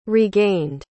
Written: regained

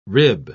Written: rib

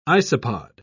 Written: isopod

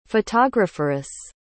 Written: photographeress

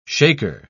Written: shaker

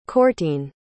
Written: cortine

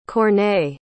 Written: Corneille

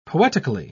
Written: poetically

